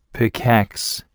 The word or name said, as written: pickax